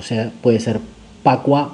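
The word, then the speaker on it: Pakua: Hay un conocimiento chino ancestral que es el conocimiento Pacua. P-A-K-U-A o se también eh, se puede encontrar con B larga la primera y G en vez de K, o sea que es Bagua,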